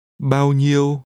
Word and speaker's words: bao nhiêu: This is pronounced with two mid level tones. Ask how much. Bao nhiêu?